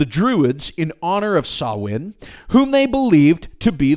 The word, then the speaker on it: Samhain: Now, it looks like Samhain, but it's pronounced Samhain.